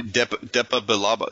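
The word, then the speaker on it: Depa Billaba: Depa Bilaba. That's fine. Go ahead. – Well, how should it be pronounced? – But now that I see the spelling, that is exactly how it should be pronounced.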